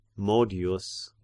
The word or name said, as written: modius